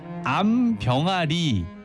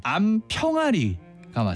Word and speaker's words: 암평아리: 우리가 병아리 암수를 구분할 때 암병아리라고 하는데 이거 아니고요. 암평아리가 맞습니다.